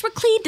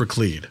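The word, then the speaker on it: Laclede: I am a pharmacist at the CVS on Raclede. – That you sure are. You sure are. And it is pronounced Raclede.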